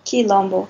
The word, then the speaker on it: Quilombo: Quilombo. – Thank you. I'm sorry. – Imagine the Q U is a K. Quilombo.